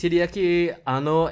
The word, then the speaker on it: Hideaki Anno: It's Hideaki Anno and Shinji Higuchi.